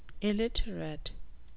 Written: aliterate

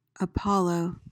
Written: apollo

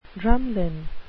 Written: drumlin

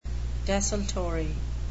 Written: desultory

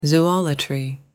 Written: zoolatry

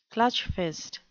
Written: clutchfist

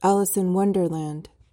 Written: Alice in Wonderland

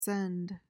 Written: scend

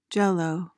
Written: jell-o

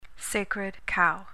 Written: sacred cow

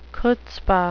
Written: chutzpah

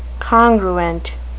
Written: congruent

